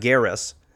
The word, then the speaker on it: Gareth: Like, Barcelona, everything has to, I have to lisp now because my name is not Gareth. It's pronounced by people with a lisp.